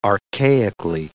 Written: archaically

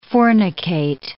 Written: fornicate

